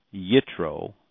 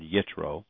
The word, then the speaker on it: Yitro: Now also what I found was this, quote, Midrashic literature asserts Jethro, Yitro, in other words, the J is silent or is utilized a hard Y sound, Yitro, converted to Judaism and called Gershel Emmet, which means convert to truth.